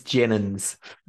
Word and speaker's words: Jenyns: It's Jenyns.